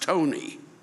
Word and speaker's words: Ptoughneigh: P-T-O-U-G-H-N-E-I-G-H. And then in parentheses, pronounced Tony.